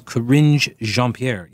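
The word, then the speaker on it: Karine Jean-Pierre: John says, I think the correct pronunciation of her name is Corinne Jean-Pierre.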